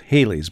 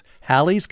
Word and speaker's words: Halley's: I used to think it was Halley's, but yeah. – I read the name rhymes with valley. So it's Halley's Comet.